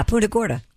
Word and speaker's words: Punta Gorda: Greg from, I assume it's pronounced Punta Gorda, Florida? – Yeah, Punta Gorda. – Not Punta? – No, it's Punta.